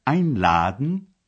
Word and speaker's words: einladen: Au présent, la particule, qui porte toujours l'accent tonique, est rejetée en fin de phrase. Voici un exemple avec le verbe einladen, inviter.